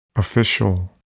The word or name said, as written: official